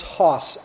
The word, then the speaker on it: Haas: And I had a dear friend just this past week tell me I was pronouncing his last name wrong. It is Haas, sort of like Haas Cartwright. Haas, Thomas Haas.